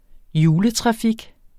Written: juletrafik

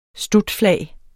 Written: stutflag

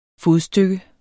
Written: fodstykke

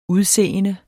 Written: udseende

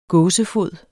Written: gåsefod

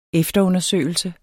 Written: efterundersøgelse

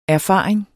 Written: erfaring

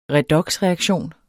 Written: redoxreaktion

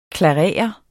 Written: klarere

